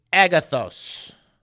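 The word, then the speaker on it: agathos (ἀγαθός): It's the Greek word good. It's agathos. Agathos. – Agathos. – Agathos. Excellent. That was excellent pronunciation.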